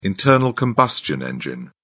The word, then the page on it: internal-combustion engine: British English